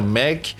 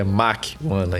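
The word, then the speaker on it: Mach 1: Descobri que os americanos falam assim, sempre falei minha vida inteira Mac. O Léo sempre falou a vida inteira Match, Match, né? Você fala, Léo? É, e, e agora descobri que é Mach-1 aí, a pronúncia do, do carro, né?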